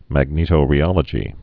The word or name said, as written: magnetorheology